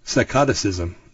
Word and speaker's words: psychoticism: are the ones that are actually linked to psychotism. Psychoticism. There we go. I knew I was saying that wrong.